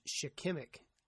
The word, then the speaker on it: shikimic: It's shikimic. I mispronounced it wrong, and I do not want to go back and redo it. It's shikimic acid.